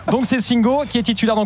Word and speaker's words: Singo: Singo, Singo, la prononciation Singo. – Singo. comme on dit Conan et pas Conan.